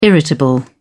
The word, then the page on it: irritable: British English